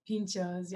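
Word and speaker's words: pintxos: I know that you can like type in like Michelin or, you know, like how do you say the word you said? Pinchos? – Pinchos. Yes.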